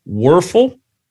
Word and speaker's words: Wuerffel: In his senior year, Joel received a prestigious, and you may have to help me with this, Werfel trophy. Did I say it right? – Danny Werfel, yeah.